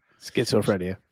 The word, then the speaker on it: schizophrenia: After about 2 years of being looked after by my dad, she has severe bipolar, can never say this, schizophrenia, and something called cytoactive disorder. – Schizophrenia.